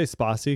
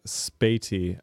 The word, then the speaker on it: Spatie: So it's, we say Sparsi because that's how you say it in Dutch or whatever. But if you're an English speaker, you'd probably spell it like spati, S-P-A-T-I-E. So it's guidelines.spati.be is where you can find that at.